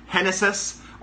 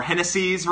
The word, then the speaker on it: Genesis: So Genesis Cabrera is actually pronounced Genesis, or Genesis, rather, Cabrera.